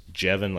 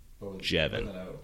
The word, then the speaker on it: Jevin: It's spelled like Javon, but it's Jevin, like Devin, but with a K or a J or... Kevin with a J is what I say. Jevin.